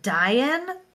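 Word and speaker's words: Dáin: There is, at that point, Azog is actually killed by Dain, who, and that is how you say it. It's not Dain.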